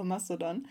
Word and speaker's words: Mastodon: Habe ich es überhaupt richtig ausgesprochen, Mastodon? – Oder Mastodon, keine Ahnung. Also der Macher ist wohl ein Deutscher. Also es ist wie Playmobil, ja, also alle denken, wer irgendwie von irgendwo, aber ist aus Deutschland. Und dann würde ich Mastodon sagen, ja, Mastodon kann man sicher auch sagen, aber ich glaube, Mastodon ist vollkommen okay, ja.